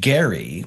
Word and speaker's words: Gerry: And it takes its name from Elbridge Gerry with a hard G, go figure.